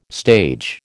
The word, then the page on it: stage: American English